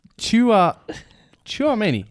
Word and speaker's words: Tchouameni: It's Tchouameni.